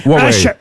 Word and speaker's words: Huawei: Comment on prononce? – Huawei. Huawei. – Ah ben voyez. – Huawei. – Qui c'est qui avait raison? C'est bien Huawei.